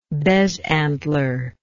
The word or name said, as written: bezantler